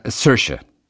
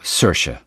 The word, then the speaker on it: Saoirse: Ich finde das, ich finde das total Und wie spricht sich die Ronan-Kollegin aus? – Äh, Sersche. – Sersche. – Genau, Sersche, Sersche. Sersche Ronan. Saoirse, Sersche.